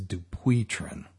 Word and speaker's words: Dupuytren: And how do you pronounce that? The French folks say it is Dupuytren.